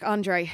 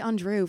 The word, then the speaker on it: Andre: And I always forget how to pronounce his second name. Eric Andre. – Yeah. – I always say Andrew for some reason.